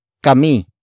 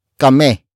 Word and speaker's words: kami: Some Tagalog speakers would relax the last I in kami, so it becomes kame. Kame. Kame. Nevertheless, it is still spelled as K A M I.